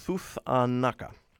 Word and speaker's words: Thuthanaka: I need to back up and say that right as I'm juggling things. Thuthanaka, an acclaimed American-Bolivian experimental music duo formed by the siblings Chiqui Mamani Kondori and Joshua Chukwimia Crampton.